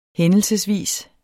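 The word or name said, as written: HÆNDELSESVIS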